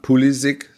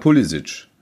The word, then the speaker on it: Pulisic: Auch da sagt der Spieler, äh, ähm, klar, ich habe äh, äh, kroatische, glaube ich, Vorfahren ähm, und die sagen halt Pulisic. So. Mhm. So, und wenn, wenn ich wenn es amerikanisch ausspreche, dann heißt es halt, dann heißt es halt Pulisic.